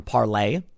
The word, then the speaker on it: Parler: There's an effort to get people on Parlay, which I use now somewhat. We'll see how it plays out. It's spelled Parler, P-A-R-L-E-R.